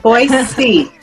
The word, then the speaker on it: Boise: We have Boise. – I said it correctly this time.